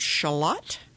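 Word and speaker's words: Schaulat: I'm here with attorney Ronald Shalott.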